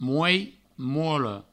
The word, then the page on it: molh: mola; mouei; mouolo